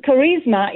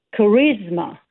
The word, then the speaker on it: charisma: And the thing with charisma is that the stress is on the second syllable. Right. So it's, you cannot say car, right? It would be charisma.